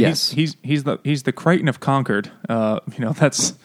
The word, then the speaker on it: Concord: You pronounced it correctly. I'm so proud of you. – How else would you pronounce it? – Concord. – The wrong way is Concord. It's not the right way to say it.